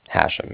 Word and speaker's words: Hashem: C-A-R-D-I-F-F, like Frank at the end. – Okay. You said your name was Hashem? – Hashem, yeah. It's H-A-S-H-E-M as in mother at the end.